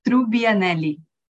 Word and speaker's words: Trubbianelli: It's Trubbianelli.